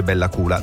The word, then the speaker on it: Bella Coola: che si scrive COOLA, perché letta così sembra un'altra roba, ma vi assicuro che si legge Bella Coola.